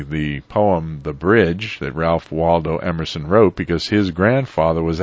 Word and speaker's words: Ralph Waldo Emerson: I should say Ralph Waldo Emerson. That's pronouncing it a little more New England style, and he's from Concord in Massachusetts.